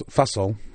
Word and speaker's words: Fussell: And it was based on a book by Sam Fussell, rhymes with muscle,